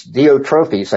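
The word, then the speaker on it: Diotrephes: I wrote to the church, but Diophrates, who loves to have the preeminence among them, doesn't receive us. That doesn't sound very good, does it? So, and this guy, Diotrophes, I guess better pronunciation, he was apparently an elder or a self-appointed leader of an unnamed assembly.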